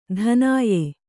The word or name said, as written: ಧನಾಯೆ